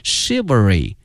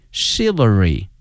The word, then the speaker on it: chivalry: 今天我们学到了侠客的这个英文的翻译，它的发音是 chivalry， chivalry。同时啊，同学们注意啊，这个词的发音哈，它跟一般的规律不一样哈，这个 ch 没有念成这个 ch 这个音，而念成这个 sh 这个音，所以同学们要小心哈，念成了 chivalry novels。